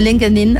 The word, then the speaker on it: LinkedIn: y eso era esta tremenda red social que es LinkedIn, otros la pronuncian distinto como LinkedIn, así,